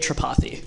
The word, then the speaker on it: Tripathi: It's Ajay Tripathi. – Ajay. Okay, Ajay Tripathi. Yeah. All right, I just have to put the emphasis on the other syllable.